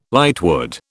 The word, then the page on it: lightwood: British English